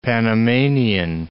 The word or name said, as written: Panamanian